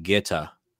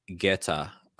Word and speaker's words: Goethe: One of his favorite poets was, I always mess up how you say his name because it's spelled differently, but it's pronounced Goethe. Johann Wolfgang von Goethe.